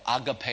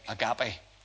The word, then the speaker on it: agape: In every instance, it's the word agape, or agape as some people like to pronounce it. I'll do it the Belfast way, agape.